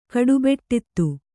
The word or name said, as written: ಕಡುಬೆಟ್ಟಿತ್ತು